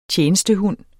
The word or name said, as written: tjenestehund